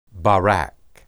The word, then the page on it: barak: Hebrew